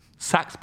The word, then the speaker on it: Saxx: He takes out of his pocket a pair of Pax boxers. Saks boxers, sorry. – Don't get the name wrong. – S-A-X-X, Saks boxers.